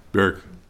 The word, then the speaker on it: Barak: And the princes of Issachar were with Deborah, even Issachar, and also, how do you say it, Barak? – Barak. – Barak.